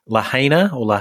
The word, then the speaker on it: Lahaina: Still a lot of people missing in the town of I'm going to pronounce this correct, Lahaina.